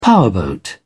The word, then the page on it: powerboat: British English